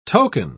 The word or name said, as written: token